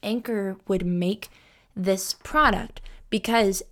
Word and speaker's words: Anker: My car charger in my car is an Anker car charger, and a lot of YouTubers have talked about them before, and I think some people pronounce it Anchor, um, but it's spelled A N K E R, and they're really great.